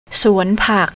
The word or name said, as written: suanpak